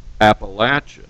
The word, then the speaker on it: Appalachian: I had to learn to pronounce it Appalachian.